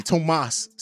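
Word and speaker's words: Tomáš: So, getting into this, Thomas Satoransky, Tomas Satoransky, excuse me, let me say his name correctly.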